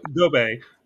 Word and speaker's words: Gobe: And he's got his big brother, Gobe. Am I saying that right? – Yeah, that's it. – Yeah, Gobe, who's a big brother, who's like the master ninja, who's also played by Robin Shu from the Mortal Kombat movie.